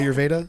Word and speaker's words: Ayurveda: Ayurveda? – That's correct.